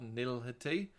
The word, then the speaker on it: nihility: It's nihilism, but nihility. Excuse me. I've butchered that. – Yeah, nihility. – There you go.